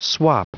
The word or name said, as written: swap